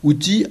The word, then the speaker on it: outil: Alors, outil, un mot vieux, c'est vrai. Ça se voit avec ce L final qu'on ne prononce pas, comme dans d'autres mots, fusil, sourcil, etc., persil.